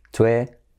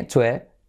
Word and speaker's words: Twitter: Pero en inglés, fijaos cómo se dice Twitter. Tenemos la I como E, Twitter, la T como R suave y luego la letra clave R, Twitter. Tú eres, como tú eres rápido, tú eres, listo, tú eres tonto, tú eres. Y ahora tenemos la R, Twitter.